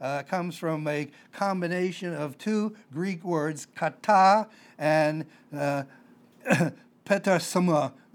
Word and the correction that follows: petasma: If I get it right, petasma, P-E-T-A-S-M-A, kind of hard to pronounce, petasma is what it says.